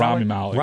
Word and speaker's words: Rami Malek: I thought the actor, is it pronounced Remy? Remy Malek? – Rami Malek. – Rami yeah.